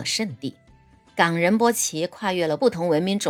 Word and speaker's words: 冈仁波齐: 说到冈仁波齐的读法呀，中间是要停顿的，也就是读作冈仁波齐。冈呢是雪山的意思，可以读成抗或者是冈都可以。